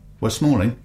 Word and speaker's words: West Malling: It's £10 for lunch, tea and coffee, and that's the Spitfire Pub, and that's in West Malling. – Yeah. – Have I said it right? – West Malling, yeah. Absolutely.